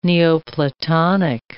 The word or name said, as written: Neoplatonic